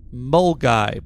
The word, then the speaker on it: Milngavie: Milngavie is pronounced Mulgai, by the way.